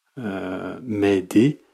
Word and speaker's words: m'aider: So Mayday is actually the English pronunciation of the French word m'aider, which means help me.